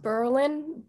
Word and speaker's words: Berlin: It's pronounced Berlin, but you spell it like Berlin, so the accent is on the first syllable.